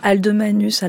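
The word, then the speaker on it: Alde Manuce: Alors Aldomanus à la française. – Aldomanus. – Ou Aldomanuccio à l'italienne.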